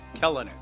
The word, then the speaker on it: Kelenic: Kelenic. Well, Kelenic. I always know it's a hard K. – No, I mean it's the Kelenic, first syllable.